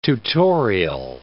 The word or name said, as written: tutorial